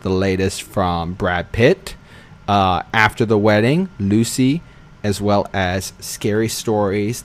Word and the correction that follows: Luce: It's pronounced Luce, not Lucy. – Oh. – L-U-C-E, Luce. – I was just going to say that too. – Loose.